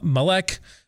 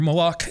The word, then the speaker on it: Moloch: And the most common intermingling, not the lone one, there was Shamash, there was uh, Malek, um, or Moloch, depending on how you want to pronounce it.